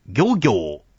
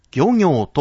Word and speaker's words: 漁業: ここは少々難しいところですが、水牛ではなくて水牛、漁業ではなくて漁業と発音します。